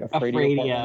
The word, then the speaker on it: Fradio: And then you've got Fradio. Is that, am I pronouncing that one right? – Fradio. Yeah, too. It's like that's radio, a... man, but it's like scary. So it's like Fradio.